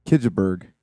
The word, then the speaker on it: Kjellberg: It's Kjellberg. Yeah. That's the American pronunciation.